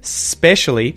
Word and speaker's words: specially: When we say the word specially, we obviously don't lengthen the S that much, but we would say just the S sound, no vowel, specially, specially. So you just have to practice not saying a vowel sound at the start, specially, specially.